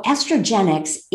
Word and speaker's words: Estrogenics: So tell us about estrogenetics. – So estrogenics is all the things in our society. – Okay. I'm sorry, I didn't say that correctly.